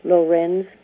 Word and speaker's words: Lorenz: He pronounced his name Lorenz Graham, and he published more than 20 books and stories by that name.